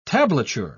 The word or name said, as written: tablature